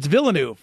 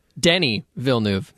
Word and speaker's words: Villeneuve: Now, in fairness, you've pronounced it wrong. If you were to pronounce this just looking at it, because it's Villeneuve, right? – It's Villeneuve. – Yeah, Villeneuve. – Denny Villeneuve, yeah.